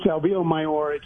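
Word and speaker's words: Albillo Mayor: Albio Mayor, is that correct? Am I saying that right? – Yes, it's Albio Mayor. It's a very obscure Spanish grape variety, but it does well here, and I've kind of been enjoying working with it.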